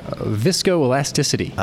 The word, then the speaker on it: viscoelasticity: Viscovascular elasticity? What is that thing called? – Viscoelasticity. – I wasn't even close.